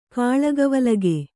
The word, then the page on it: ಕಾಳಗವಲಗೆ: Kannada